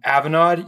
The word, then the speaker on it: Avanade: Is it Avanade? – Avanade, yeah.